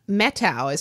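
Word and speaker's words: Methow: Mettow is what I'm told.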